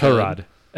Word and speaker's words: Tyrod: Tyrod. Everybody's been saying his name wrong. Don't you watch Hard Knocks? – Tyrod. – Tyrod. – Tyrod. – Tyrod Taylor.